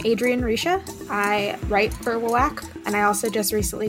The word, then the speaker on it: WWAC: I'm the editor-in-chief of WWAC. There is some debate as to how that's pronounced, but I pronounce it WWAC.